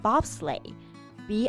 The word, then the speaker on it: bobsleigh: 那雪车就不叫做 snow car，而是叫做 bobsleigh，b o b s l e i g h。那结尾的 g h 就是不发音的还有一个项目，我觉得也很好玩，就是雪橇 luge.